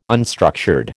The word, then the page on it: unstructured: American English